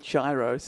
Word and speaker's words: Cheiro: Oh, that was it's spelt very differently, but yes. It's probably Chiro, C-H-E-I-R-O, Chiro.